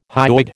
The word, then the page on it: hyoid: American English